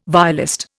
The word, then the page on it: violist: Indian